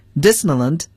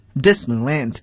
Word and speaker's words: Disneyland: Disneyland 通常呢，就是这个如果 land 它作为这个名词的后缀时呀，我们既可以读作 land，也可以读作 lent Disneyland, Disneyland 都可以，迪士尼乐园。